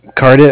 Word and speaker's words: Cardiff: Cardiff. I know it sounds weird over the phone. It's like Cardiff, Wales, C-A-R-D-I-F-F.